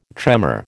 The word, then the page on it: tremor: American English